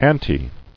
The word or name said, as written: Aunty